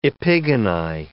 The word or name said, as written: epigoni